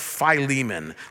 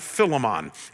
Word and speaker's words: Philemon: It's the New Testament book of Philemon. Let's pronounce it right. It's not Philemon, it's Philemon.